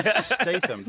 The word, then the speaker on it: Statham: Statham.